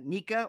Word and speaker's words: NECA: One of the few things that surprised me about Toy Fair is Nika, or N-E-C-A is actually the way you're supposed to pronounce it, but everybody still says Nika,